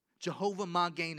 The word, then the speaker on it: Jehovah Magen: Jehovah Magen is the way it's pronounced in the Hebrew.